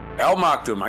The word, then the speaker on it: Maktoum: The remarkable journey will be 10 times the distance covered by the Hope probe, said Al Maktur, Al Maktoum, I get his name right, referring to the AE, mission to Mars in February 2021.